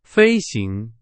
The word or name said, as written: 飞行